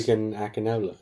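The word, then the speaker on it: Segun Akinola: Sagan Akinola.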